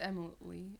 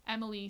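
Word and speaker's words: Emeli: Is that how you pronounce it? – I think it's Emily.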